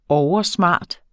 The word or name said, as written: oversmart